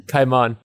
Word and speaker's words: Kaiman: Trauerweide oder Cayman. – Kaiman, okay, Entschuldigung.